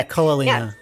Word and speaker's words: Ko'olina: I don't know if I said that properly. – Yeah, Ko'olina. Yeah. – Ko'olina. Okay, perfect.